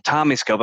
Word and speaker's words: TOMi Scope: But the TOMi Scope, am I saying that correctly? – That's right.